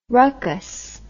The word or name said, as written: rucus